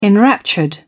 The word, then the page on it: enraptured: British English